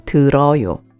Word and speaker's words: 들어요: 듣다 in the present tense, 고 conjunctive is 듣고, and 듣다 in the present tense in the informal polite style is 들 followed by 어요, which is pronounced 들어요.